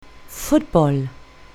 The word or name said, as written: football